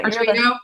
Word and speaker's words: Arduino: And we featured him on one of our blog posts that he Arduino. How do you say that? Because you're the Arduino.